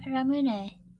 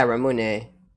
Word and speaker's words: Ramune: Ramune. – Yeah, Ramune.